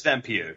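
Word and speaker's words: Vampyr: I believe that's Vampire.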